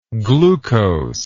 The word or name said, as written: glucosic